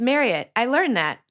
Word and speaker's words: Marriott: It's Marriott rhymes with chariot.